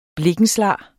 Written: blikkenslager

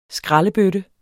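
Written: skraldebøtter

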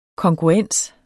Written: kongruens

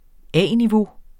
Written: A-niveau

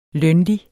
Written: lønlig

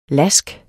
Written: lask

